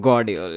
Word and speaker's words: Guardiola: And that's how it turned out, because, yes, he won the penalty, but that he turned Gravidal like upside down for that third. – Guardiola. – Yeah, sorry. I always get his name wrong.